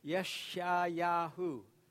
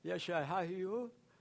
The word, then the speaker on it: Yeshayahu: It sounds a little bit like Yeshua, but it's Yeshayahu. Repeat after me. Yeshayahu.